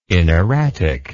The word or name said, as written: inerratic